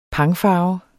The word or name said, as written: pangfarve